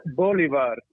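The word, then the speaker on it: Bolívar: Bolivar. So the I has a high, like an iron.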